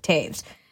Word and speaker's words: Toews: I've been saying their last name wrong in all kinds of ways, but it is Toews.